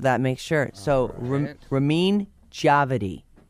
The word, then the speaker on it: Djawadi: Djawadi.